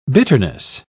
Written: bitterness

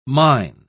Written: mine

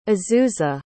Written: azusa

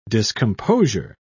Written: discomposure

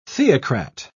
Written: theocrat

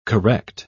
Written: correct

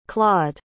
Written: claude

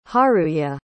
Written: haruye

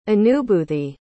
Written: anubhoothi

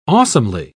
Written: awesomely

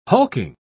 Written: hulking

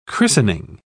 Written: christening

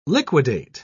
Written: liquidate

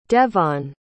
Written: Devaughn

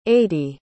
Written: aidy

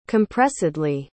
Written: compressedly

